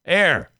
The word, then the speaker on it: heir: Heir. Like heir to the throne. – Heir, of course, yeah. Why can't they just spell heir then? It's that's not heir. Then this is like the kingdom. – Silent H. Yeah.